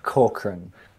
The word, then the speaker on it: Corcoran: CORCORAN.